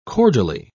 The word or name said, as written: cordially